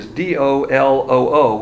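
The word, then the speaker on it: dol-o-o (δολόω): The word adulterating is D-O-L-O-O, which is pronounced dol-o-o, which means to share, to handle deceitfully.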